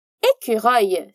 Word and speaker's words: écureuil: Écureuil se escreve E-C-U-R-E-U-I-L. E tem três sílabas. E a primeira delas é o E, como em étoile, estrela. O mesmo som que temos em português, E. Depois, temos o som Q, como na palavra culture, culture.